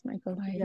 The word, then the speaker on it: Michaelides: Michaelides.